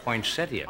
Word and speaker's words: poinsettia: Anyway, poinsettia. – I'm telling you, the I is silent.